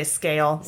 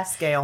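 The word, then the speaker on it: Sgaeyl: Help me pronounce her name. Is it Scale? – I'm just gonna say Scale. Like Gale with an S. – Yeah, Scale.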